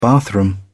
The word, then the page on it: bathroom: British English